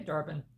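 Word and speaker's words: Dar-Benn: Darben? Is that how you pronounce it? – Yeah, Darben.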